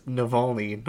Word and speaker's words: Navalny: Oh, that's actually how it's pronounced as well, as Navalny, not Navalny.